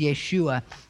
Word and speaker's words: Yeshua: And really, they don't pronounce their J's. There is no J in Hebrew. And so really what we see is Yeshua. And it's a form of Yeshua.